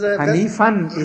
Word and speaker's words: Hanifa: Because Hanifan is the word, because when it's used in when it happens in the end, then you don't pronounce the tanween, that is noon part. So this is why it's called Hanifa.